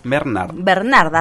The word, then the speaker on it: Bernhard: No siempre pronuncio mal tu apellido, Valen. Ay. – Bernard, así nomás, Bernard. Pero tiene una H en el medio que siempre solemos como el Bernhard, no sé, una cosa así, pero es Valen, Bernard y Seba Cabo.